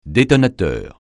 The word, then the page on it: détonateur: francais